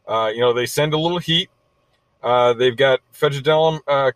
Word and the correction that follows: Fejedelem: coming off. I added a syllable there. Excuse me. All right. Fegidellum's coming off the edge.